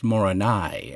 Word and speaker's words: Moroni: Whether we're actually pronouncing the name correctly by employing an English or anglicized idiom, or whether the name ought to be pronounced Moroni, emphasis on the last syllable, according to Mesoamerican language patterns, can be discussed in another podcast.